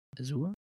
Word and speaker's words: Azure: Azure. – Und wie wird das überhaupt ausgesprochen? Azure? – Azure. – Sicher? – Ja. – Ich dachte mal Azure oder so.